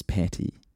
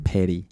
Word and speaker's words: Patty: Do you know what I was really cognizant of coming in today is that in the Kiwi accent, and I have this very badly, we often pronounce T's as D's, and your name is Patty. – Patty. – And now I'm over enunciating it. but my default would be like petty.